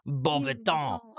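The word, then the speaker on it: Beauxbatons: That's what it looks like, but it's French, so you can say it with a silly, fake French accent, like, Bobaton.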